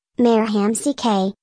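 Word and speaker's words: Marek Hamšík: Mark Hamsik, Slovak pronunciation, Mayor Hamsik, born July 27, 1987, is a Slovak professional footballer who plays as a midfielder for Super League club Trabzonspor.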